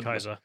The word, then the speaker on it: Caesar: Don't they also pronounce it Kaiser as well? Just to make sure. – Kaiser.